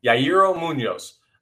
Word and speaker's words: Yairo Munoz: Bermudo catching, Gregorius playing shortstop, Bohm at third, then Yair Munoz, let's say his name right. Yairo Munoz. I keep wanting to say it like Frankie Muniz from uh, from Malcolm in the Middle.